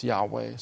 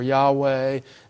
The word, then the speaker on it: Yahweh: But most scholars today think that it was pronounced Yahweh.